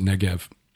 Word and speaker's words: Negev: It's spelled Negeb, properly. But we pronounce it Negev.